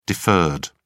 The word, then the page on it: deferred: British English